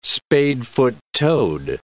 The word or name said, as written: spadefoot toad